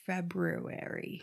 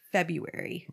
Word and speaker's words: February: I don't think that people say February. – This guy does. If it's like in there, it's being pronounced. – People say February. – Right. – So why is there an R in there? That always messed me up when I was a kid.